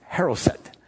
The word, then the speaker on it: charoseth: It's not spelled, it's not spelled like that. It's spelled C-H-A-R-O-S-E-T-H, but it's pronounced haroset.